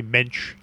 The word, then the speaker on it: Moench: But so then Doug, am I pronouncing this right? Moench? – I think generally Mensch. Is Steffi how you Doug Mensch? He's a Mensch. – Doug Mensch, I think, is how you say it.